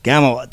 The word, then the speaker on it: Gamuel: And right now we have Gamuel. Did I say that right? Gamuel? – Yeah.